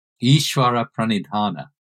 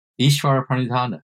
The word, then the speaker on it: Ishvara Pranidhana: And also, please correct my Sanskrit pronunciation of that term. – Ishvara Pranidhana. Ishvara Pranidhana.